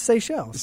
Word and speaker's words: Seychelles: It's Seychelles. – Seychelles. Okay. – Right. – So I've heard seashells, Seychelles, pretty much just those two.